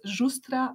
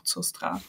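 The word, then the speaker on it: Žustra: Also ich bin gebürtige Kroatin und eigentlich wird es Justra ausgesprochen und Justra geschrieben, also mit so einem kleinen Haken über dem Z, aber es wurde dann einfach wegrationalisiert und jetzt ist es einfach nur noch Zustra.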